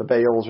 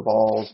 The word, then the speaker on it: Baals: We talked about some of the stuff with the Baals or the Balls, depending if you're from Alabama or from Israel is how you're going to pronounce those two things.